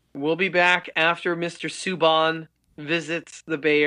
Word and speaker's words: Subban: Oh, is it Subban? – Yeah. So now he wants his name to be pronounced Subban.